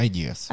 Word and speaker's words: Ideas: Да, я бы тогда еще раз вот уделила единственное, что как и Moa Ideas и или Ideas, да, скажи мне, как правильно, да, ударение? – Второе, Ideas. Ideas.